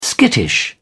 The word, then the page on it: skittish: British English